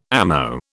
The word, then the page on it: ammo: British English